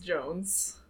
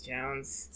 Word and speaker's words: Jonze: It's Jonze. – But it's Jones. – Spike Jones.